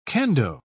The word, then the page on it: kendo: British English